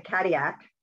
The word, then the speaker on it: Cadillac: But this one little town, it's spelled Cadillac, and they actually have this in the wine store I went, but they pronounce it Cadillac.